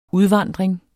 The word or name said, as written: udvandring